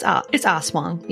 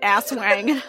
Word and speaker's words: Aswang: That's because some Americans do say asswang.